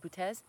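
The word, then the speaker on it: Boötes: Like, it's spelled like booties, B-O-O-T-E-S, Butes, and it means the coming.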